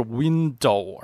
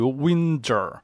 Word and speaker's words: window: 说他呀，本来唱 window shopping 这个词的时候，他的发音不是 window，而是类似于 winter winter shopping。